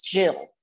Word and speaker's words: Gyl: I did want to say that my name is spelled G-Y-L, but it's Jill.